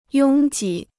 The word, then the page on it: 拥挤: yōng jǐ